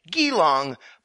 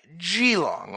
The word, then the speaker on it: Geelong: which actually isn't pronounced with a hard G like Geelong, but a soft G like Geelong, or actually, as Kitty puts it, quote, think Jesus or giraffe, but without the stresses, end quote.